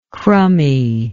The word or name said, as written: crumby